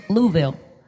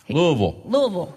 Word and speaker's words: Louisville: Did I say that right? Louisville? – Louisville. – Louisville. There you go.